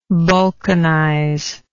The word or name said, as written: balkanism